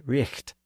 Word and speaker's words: right: It was traditionally pronounced something like Richt.